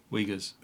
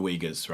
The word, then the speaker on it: Uyghurs: Uyghurs. – The Uyghurs, right? – It started beyond earlier than that.